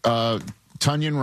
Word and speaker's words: Tonyan: You know, they got Robert Tonyan, rhymes with Funyan, to go along with the wagon, Darnell Washington, and of course, Freiermuth.